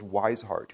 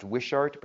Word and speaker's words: Wishart: And then secondly, there is this man named George Wisehart, who began a ministry as a traveling preacher. It might be pronounced Wishart, but I like to say Wisehart, so I'm going to say Wisehart. Anyhow, George Wishart.